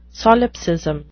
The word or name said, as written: solipsism